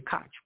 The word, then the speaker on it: Koch: If you took the Some pronounce it as the Koch brothers. Some pronounce it as the Koch brothers. K-O-C-H.